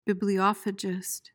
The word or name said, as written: bibliophagist